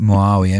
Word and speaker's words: Muawiyah: Muawiyah, M-U-A-W-I.